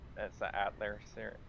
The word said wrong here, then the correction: Atelier: Ah, Atelier. It's French.